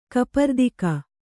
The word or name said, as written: ಕಪರ್ದಿಕ